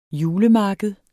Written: julemarked

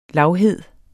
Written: lavhed

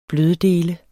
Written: bløddele

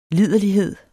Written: liderlighed

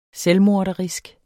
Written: selvmorderisk

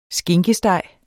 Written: skinkesteg

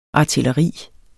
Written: artilleri

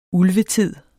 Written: ulvetid

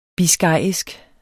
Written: biscayisk

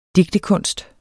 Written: digtekunst

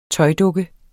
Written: tøjdukke

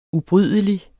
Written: ubrydelig

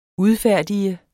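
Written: udfærdiget

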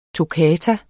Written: toccata

